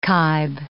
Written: kibe

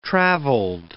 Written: travelled

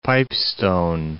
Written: Pipestone National Monument

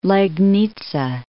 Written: Legnica

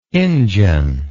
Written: injun

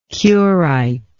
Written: curite

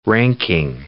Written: ranking

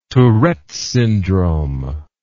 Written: Tourette's syndrome

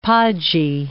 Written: podgy

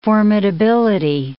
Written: formidability

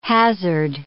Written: hazard

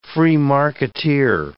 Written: free marketeer

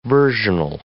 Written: versional

